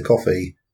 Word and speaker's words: Coffey: I should point out that whilst my name is Peter Coffey, it is spelt differently. – Yes. I thought more like the drink.